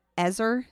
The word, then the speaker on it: ezer: And this word helper is the Hebrew word ezer. Is that how you say it? – I think so. – E-Z-E-R.